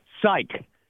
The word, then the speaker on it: psych: So the Word was, rhymes with you, Ike and Spike. Psych.